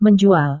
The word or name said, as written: menjual